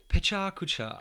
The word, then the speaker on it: Pecha Kucha: I'm trying to get people to pronounce it right. – See it again. – Pecha Kucha. Pecha Kucha, it's Japanese.